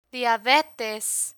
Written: diabetes

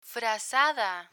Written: frazada

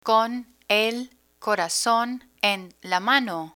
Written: con el corazon en la mano

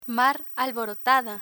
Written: mar alborotada

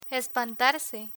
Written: espantarse

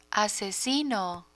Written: asesino

